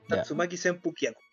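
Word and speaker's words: Tatsumaki Senpukyaku: Ya no lo diga mal. Usted no lo diga, como decía el profesor Bandera. – Pronúncelo bien, como corresponde. – ¿Cómo sería entonces? – Papouken, Shoryuken y Tatsumaki Senpukiano.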